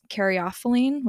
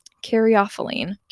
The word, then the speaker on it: caryophyllene: And the main terp in runts is caryophyllene, which I just now recently learned is the correct way to pronounce it. I was saying caryophyllene for a long time.